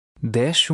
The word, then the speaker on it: desce: In the first word, the SC is pronounced like the end of fish. – Desce uma ladeira a colar.